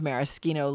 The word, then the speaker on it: maraschino: It is maraschino because now, so many years later, I realized the proper way to say CH in Italian. Okay? CH versus American way of saying CH. – And how do you say that? – In Italy, a C and an H always is a hard C.